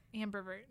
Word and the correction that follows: ambivert: There's no, you said per, and that sounds like pervert, and that's not it. – Wait, how do you say it? – I think it's ambivert. It's like A-M-B-I-V-E-R-T.